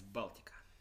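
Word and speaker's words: Baltika: Uh, it's Baltica. Baltica. Yeah. Baltica, like the Baltic Sea.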